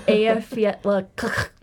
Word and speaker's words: Eyjafjallajökull: It's Eyjafjallajökull.